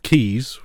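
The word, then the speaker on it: Caius: but they'll produce the also pronunciation of Keys, which is another pronunciation of it, which is actually because there's a college in Cambridge where I'm from called Gonville and Keys, and it's spelled Gonville and Gaius.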